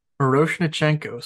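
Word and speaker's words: Miroshnichenko: Miro Shen Nichenko, Murashinkinichenko. Say it for me. – Moroshnichenko, sir. – Yeah, so I was close enough.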